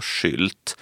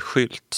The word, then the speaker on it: Schildt: Kollar man på hur det stavas skulle det vara Schildt, men enligt släkttraditionen så uttalas det Schildt som en helt vanlig trafikskylt.